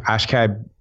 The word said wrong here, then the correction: Akshay Bhatia: Do you remember the guy that played in the Valspar? – No. – You said his name, so I didn't have to. – Did I say it correctly? – Yeah, the left-hander, Akshay Bhatia.